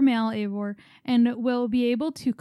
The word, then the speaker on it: Eivor: Okay, so it's pronounced Eivor. – Eivor.